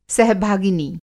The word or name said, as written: सहभागिनी